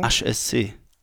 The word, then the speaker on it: HSC: okay, also das H wird eigentlich Asch ausgesprochen, wenn man es buchstabiert im Französischen. – Also wie müsste ich es dann sagen? HSC.